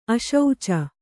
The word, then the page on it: ಆಶುಚಿ: aśuci